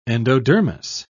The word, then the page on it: endodermis: British English